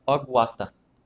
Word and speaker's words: Ogüata: E ele é CEO da Ogwata. Falei certo? Isso, beleza. Aguata, que é uma consultoria de treinamento em inovação.